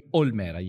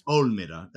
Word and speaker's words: Olmera: Do you pronounce it Olmera or Olmera? – Olmera, yes. – Olmera.